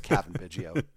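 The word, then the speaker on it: Cavan Biggio: I pronounced him like the burger place in Detroit. It's Kevan Biggio.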